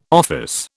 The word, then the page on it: office: British English